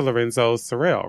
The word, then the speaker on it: Lorenzo Cyril: Mr. Lorenzo Cyril, right? – Correct.